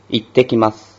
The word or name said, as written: ittekimasu